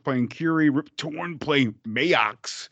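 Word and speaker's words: Maax: It's like Max is how they say it, but it's like it's just Max with an extra A. Come on, guys. – Yeah, they put like two A's on it, but it's Max. John Amos, the great John Amos, playing Seth.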